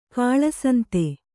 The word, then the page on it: ಕಳ್ಳಸಂತೆ: kaḷḷasante